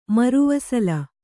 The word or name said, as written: ಮರುವಸಲ